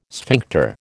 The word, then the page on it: sphincter: American English